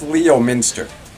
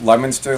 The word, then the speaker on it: Leominster: It's spelled Leominster, and that's how people who don't know it pronounce it, but it's Leominster, like you take a lemon and you stir it.